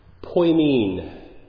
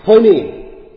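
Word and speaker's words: poimen (ποιμήν): The Greek word, here, if you get a little Greek lesson this morning, poimen. Poimen. Maybe I'm not pronouncing that right, but I say I am. So, poimen. There you go. Unilaterally. This is how it is supposed to be pronounced. Poimen.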